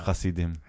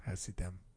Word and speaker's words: Hasidim: Is that how you say it? – Plural. Oh. Hasidim. – Hasidim.